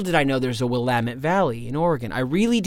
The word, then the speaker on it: Willamette: And he's like, no, it's Willamette Mountain. It's pronounced Willamette.